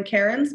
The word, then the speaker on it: Cairns: You can find me on Instagram at Lauren Cairns. My first name is spelled L-O-R-E-A-N, last name C-A-I-R-N-S, or through my brand, Fox and Jane Salon Group.